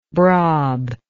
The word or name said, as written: brob